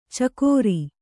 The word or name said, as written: ಛಿಕ್ಕಾರ